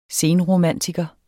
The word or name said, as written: senromantiker